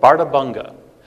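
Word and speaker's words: Bárðarbunga: In addition to this El Niño, we had the eruption of a volcano called Bartabunga. I see no D in the spelling of Bartabunga, but nonetheless, that is how it's pronounced.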